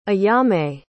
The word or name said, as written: ayame